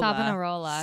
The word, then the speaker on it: Savonarola: And this was all instigated by the Dominican friar, let me see about this pronunciation, Girolamo Savonarola. – Savonarola is how you say his last name.